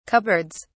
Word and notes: cupboards: British English

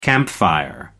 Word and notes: campfire: American English

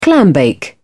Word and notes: clambake: British English

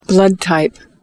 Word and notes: blood type: American English

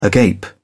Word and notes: agape: British English

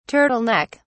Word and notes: turtle neck: British English

